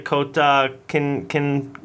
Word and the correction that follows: Kota Kinabalu: Kota, Kota Kinabalu in Borneo Island.